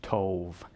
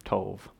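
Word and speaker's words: tov (טוב): I just want to take a second and point out that that word good is in Hebrew, that word is pronounced tov, and that'll be important as we go later on. Um, and I just think that's fun. So there you have it, tov.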